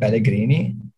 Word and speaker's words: Pellegrini: Was it Pellegrini? – Yeah, no.